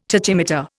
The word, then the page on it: tachymeter: Indian